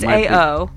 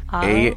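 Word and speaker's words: ao: How do you say cloud? A-O? It's A-O. – A-O. Is it A-O?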